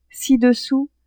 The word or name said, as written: ci-dessous